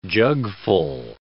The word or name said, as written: jugful